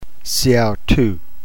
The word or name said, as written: seautou